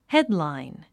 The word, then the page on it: headline: hédlàin; ヘドライン